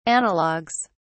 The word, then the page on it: analogs: British English